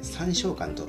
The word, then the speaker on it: 三松館: があったということをお話ししたんですが、えー、っと市川市に、えー、できた最初の映画館というのが先ほどはですね私三松館というふうに言いましたけど、えー、 3 つの松の館で三章館と読むそう